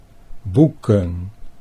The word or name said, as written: boeken